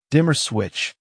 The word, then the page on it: dimmer switch: American English